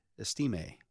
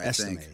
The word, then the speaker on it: Estime: And five is Audric Estime. – Oh, the Notre Dame dude. – Yeah. – Okay. – Am I pronouncing that right? – Estime, I think. – He's got the little flair on the end.